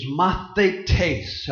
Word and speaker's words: mathetes: But the word disciple in the Greek is mathetes. I suppose you can spell it in English, M-A-T-H-E-T-E-S.